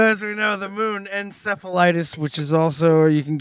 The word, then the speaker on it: Enceladus: And because there's some moons in the universe like Europa, which is around Jupiter, and Enceladus, which we've been butchering the pronunciation of that for a few episodes, but that's how it's pronounced, Enceladus.